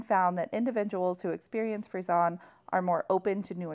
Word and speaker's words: Frisson: It looks like Frisson. It's Frisson. – She just pronounced it incorrectly Frisson. Every time. It sounds like a breed of a dog.